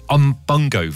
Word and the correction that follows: Umbongo: Someone's texting saying it's not Umbungo, it's Umbongo.